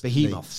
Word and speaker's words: behemoths: You say Behemoths. – Behemoths. So now that's in my head as the way to do it.